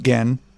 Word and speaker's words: Gen: But I found it, I typed in Barefoot Gen, which is G-E-N, by the way. It's a hard G.